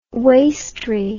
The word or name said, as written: wastery